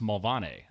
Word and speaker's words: Mulvaney: So, My name is Scott William Mulvaney. Well, over there, up in Belfast, it's pronounced Mulvaney, apparently.